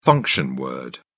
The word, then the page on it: function word: British English